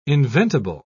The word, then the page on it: inventible: British English